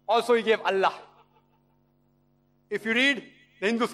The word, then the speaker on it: Allah: The name, one of the attributes of God Almighty is Allah. They write it as I-L-A. But when you pronounce it, we have to tell them, pronounce it as Allah.